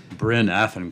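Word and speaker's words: Bryn Athyn: University of Maryland, and how do you pronounce that word? Burn Athen College. You know, when it's international, I got to pronounce it properly. So you get that mic there for me, and you pronounce the name of your college properly. – Bryn Athen College.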